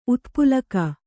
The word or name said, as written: ಉತ್ಪುಲಕ